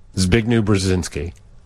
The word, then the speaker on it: Zbigniew Brzezinski: Zbigniew Brzezinski.